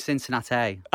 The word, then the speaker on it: Cincinnati: And for those who do kind of like pull us up on our pronunciations and stuff, my apologies, because I do believe it's actually Cincinnati. – No, it's Cincinnati.